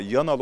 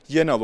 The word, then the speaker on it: Yanal: Çalıştırdığı takımların başarısından mıdır bilinmez ama Yanal olan soyadı uzun süre Yenal olarak telaffuz edildi.